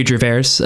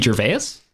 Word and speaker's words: Gervais: Um, Ricky Gervais. Ricky Gervais. You, you added a weird extra R at the end of Gervais.